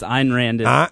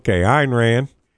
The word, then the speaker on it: Ayn Rand: Ayn Rand, how are you pronouncing her name? – It's Ayn Rand. – Ayn Rand.